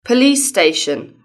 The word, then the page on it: police station: British English